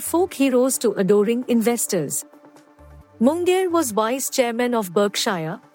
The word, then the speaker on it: Munger: A lawyer by training, Munger, rhymes with hunger, helped Buffett, who was 7 years his junior, craft a philosophy of investing in companies for the long term.